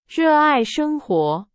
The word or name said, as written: 热爱